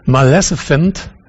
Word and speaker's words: Maleficent: Wie spricht man es richtig aus? Maleficent. Malefiz.